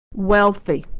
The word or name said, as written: wealthy